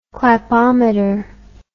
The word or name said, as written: clapometer